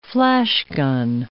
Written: flashgun

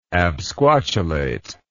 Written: absquatulation

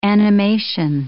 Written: animation

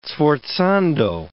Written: sforzando

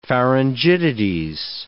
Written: pharyngitides

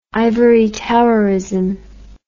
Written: ivory-towerism

